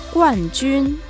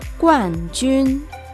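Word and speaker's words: Guan Jun (冠军): Campeón en chino es Guan Jun. Guan Jun.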